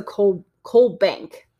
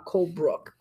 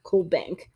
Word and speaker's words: Colbank: So they do have a police detail on this, and her name is, I keep wanting to mispronounce it, so Jessica Colbank, not Colebrook, Colbank,